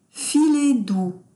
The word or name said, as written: filer doux